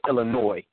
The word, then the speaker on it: Illinois: And Titan, for the record, we don't put an S on our state the sound of an S. It's spelled with an S, but we don't use the S. It's just Illinois, like toy, oh.